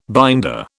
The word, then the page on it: binder: British English